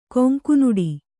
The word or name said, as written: ಕೊಂಕುನೋಟ